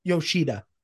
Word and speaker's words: Yoshida: How do you say his name again? – Yoshida. – Yoshida.